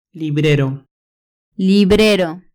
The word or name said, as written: librero